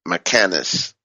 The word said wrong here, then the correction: mechanistic: mechanic, mechanist. I always mess that word up. But anyways, in other words, it's not mechanical. Mechanistic, excuse me, I just had to say it right.